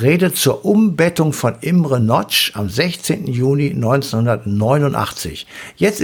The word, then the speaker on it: Nagy: Geschrieben Nagy, falls irgendwie. Nagy, aber Notch ausgesprochen. Also Nagy ähm, ist richtig.